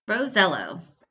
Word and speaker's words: Rosiello: He asked his Italian grandfather years ago, he confirmed it, Rosiello.